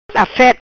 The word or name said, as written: Afet